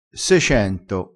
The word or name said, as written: secento